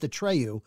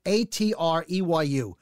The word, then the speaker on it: Atreyu: It's pronounced Atreyu. Atreyu.